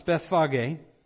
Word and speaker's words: Bethphage: Now, when the day drew near, or rather, when they drew near to Jerusalem and came to Bethphage, is how it's pronounced, Bethphage,